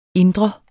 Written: indre

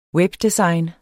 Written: webdesign

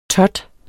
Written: tot

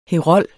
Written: herold

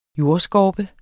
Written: jordskorpe

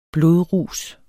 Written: BLODRUS

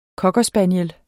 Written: COCKER

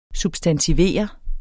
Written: substantivere

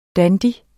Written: dandy

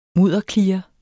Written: MUDDERKLIRE

